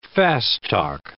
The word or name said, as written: fast-talk